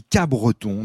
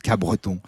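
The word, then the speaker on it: Capbreton: Attention, en général, ici, on dit Cabreton, on ne prononce pas le P, ou alors très légèrement, Cabreton.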